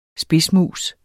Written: spidsmus

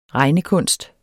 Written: regnekunst

